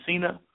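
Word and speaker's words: Encinia: Enesian. I don't know how to pronounce it. Is it Encina? – Yeah. – Yes, that's what it looks like.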